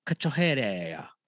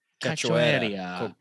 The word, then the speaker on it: Cachoeira: Ji Yeon Kim versus Priscilla Cachoeira. I'm saying that wrong. How do you say that? – Cachoeira. Cachoeira. Cachoeira.